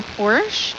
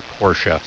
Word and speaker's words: Porsche: Porsche or Porsche? – Well, the German pronunciation is Porsche, so it depends if you feel comfortable in other languages. Yeah, exactly. – So it's up in the air a little bit. – I think it's a two-syllable word, if you ask me, yeah.